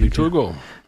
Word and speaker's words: Victor Hugo: Victor Hugo. Ich hoffe, ich habe das richtig ausgesprochen. – Ja, Victor Hugo.